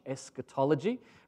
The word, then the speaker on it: eschatology: They're spelled with an E-S-C-H, eschatology, from the Greek eschatos, meaning end or final.